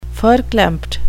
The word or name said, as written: verklempt